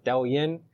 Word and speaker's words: Douyin: If you get this Chinese app, I don't speak Chinese, but I think it's pronounced Douyin.